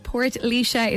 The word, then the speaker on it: Portlaoise: So I got in touch and said, am I right that we all say Portlaoise when we should be saying Portlaoise? – Hey. Yep, you are correct. Portlaoise is how it's pronounced, Oscailge. Portlaoise is the genitive case, so that translates to the fort of Laois. And in the Irish Standard, it's pronounced like Portlaoise. – Portlaoise.